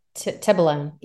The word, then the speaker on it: Tibolone: And then Tibella, I'm not sure if I'm saying that right. – Tibolone. Yeah.